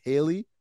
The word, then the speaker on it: Halle: And now you guys tell me it's not even pronounced Halle. It's Haley.